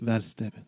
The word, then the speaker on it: Valstevin: Talia, does she know this Destevin, is he... – Valstevin.